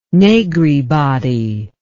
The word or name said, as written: Negri body